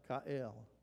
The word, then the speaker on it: goel: And it's spelled G-O-E-L, but it's pronounced Ka'el. And there is a tremendous attachment on Ga'el.